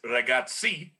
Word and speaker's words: ragazzi: I can tell you it's Ragazzi. – There you go. So see, yeah. – Well, I mean, you look at the word pizza, it has two Z's in there, but you pronounce it like a hard Z, like it's an S sound. So pizza. – Pizza. Pizza. Okay. Yeah. – So Ragazzi.